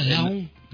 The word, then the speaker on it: Laon: Ça s'écrit L-A-O. Ah, Laon. – Laon Ah,